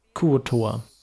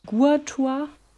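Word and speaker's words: Kuo-toa: Kuotoa. – Guatoa? Kuotoa.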